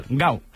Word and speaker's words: Gal: sino Gal, que en portugués de Brasil se pronuncia igual, gau, con U final. A